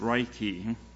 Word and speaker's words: Reiki: And it's pronounced Reiki, and it's Japanese,